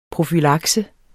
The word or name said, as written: PROFYLAKSE